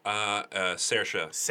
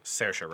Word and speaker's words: Saoirse: And I chose, I never can pronounce her name correctly, Saoirse Ronan? – Saoirse. I believe is the pronunciation. – Saoirse Ronan.